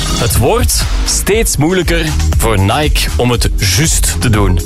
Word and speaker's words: Nike: En Nike, of Nike, ja, zo moet je dat eigenlijk uitspreken, wordt steeds minder populair, zo blijkt.